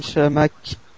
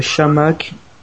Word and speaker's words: Chamakh: l'attaque Chamac pour pour ceux qui le prononcent à la française. Donc voilà, Marwan Chamakh et Johan Gouffran en attaque, Exit Kavenaghi.